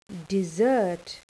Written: dessert

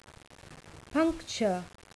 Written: puncture

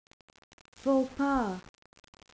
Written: faux pas

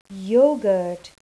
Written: yoghurt

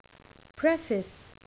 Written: preface

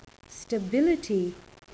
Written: stability

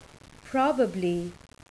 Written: probably

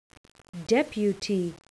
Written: deputy

